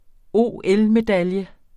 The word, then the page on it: OL-medalje: ˈoˀˈεlmeˌdaljə; ˈoˀˌεl-